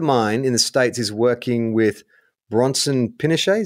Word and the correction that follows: Pinchot: is that how you say his name? – Is it Pinchot? – Pinchot. Pinchot? Serge. Pinchot.